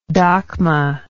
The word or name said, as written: dakhma